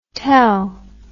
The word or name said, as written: tel